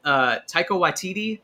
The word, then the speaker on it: Waititi: But, and then Taika Waititi, is that how you say his name? – Yes.